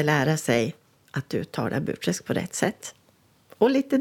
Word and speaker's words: Burträsk: Och jag vill gärna lära sörlänningarna hur man uttalar just ordet Burträsk. För många sörlänningar säger ju Burträsk, men så heter det inte. Det vore ju precis som att säga Västerås. Det går ju inte, utan Burträsk. Det är flera som har sagt att de önskar att boken ska bli inläst som talbok.